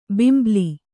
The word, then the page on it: ಬಿಂಬಲಿ: kannada